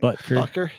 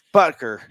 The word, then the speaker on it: Butker: Apparently, she was a Kansas City Chiefs fan, and her favorite player was the kicker, you know, Dubker. I can't pronounce his last name. – Butker. – Yeah, Butker.